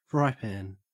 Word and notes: frypan: Australian English

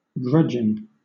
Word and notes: grudging: British English

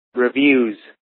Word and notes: reviews: American English

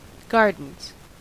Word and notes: gardens: American English

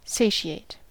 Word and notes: satiate: American English

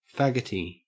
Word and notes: faggoty: Australian English

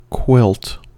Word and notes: quilt: American English

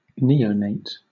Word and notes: neonate: British English

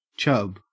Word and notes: chub: Australian English

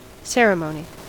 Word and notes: ceremony: American English

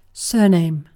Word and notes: surname: British English

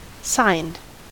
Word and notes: signed: American English

